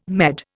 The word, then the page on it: Med: Indian